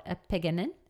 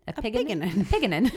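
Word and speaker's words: apigenin: The first one I'll highlight is epigenin, and I'm not sure if I'm butchering these, but it's A-P-I-G-E-N-I-N. I'd call it epigenin. – Epigenin. That's sure. Cute. – It is a cute way of saying it. It might be epigenin, but we'll see.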